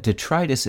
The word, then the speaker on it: detritus: If you use detritus in speech, remember to stress the second syllable, as you do in the words arthritis and bronchitis. Once you've mastered its meaning and pronunciation, you'll find that detritus is a term,